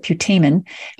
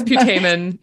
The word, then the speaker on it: putamen: no, actually they pronounce it putamen. I had to look this up when I did the audio. Yeah, it, it's putamen.